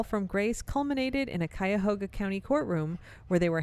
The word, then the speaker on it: Cuyahoga: Excellent pronunciation of Cuyahoga.